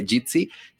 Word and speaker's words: Jitsi: Bueno, eh, se pronuncia Jitsi y eh,